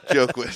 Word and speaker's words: Joaquin: Yeah, you're saying it right. Hell yeah! – How do you say it? – Joaquin. – Joaquin. Why did I say joke? – Because it's spelled with a J.